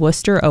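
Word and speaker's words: Wooster: So thank you so much to Matt and Julie in Worcester, Ohio. Worcester, not spelled like the Massachusetts.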